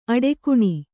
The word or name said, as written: ಅಡಿಗಾಣ್